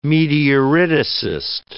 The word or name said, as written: meteoriticist